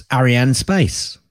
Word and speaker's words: Arianespace: It's Arianespace.